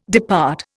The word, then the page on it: depart: Indian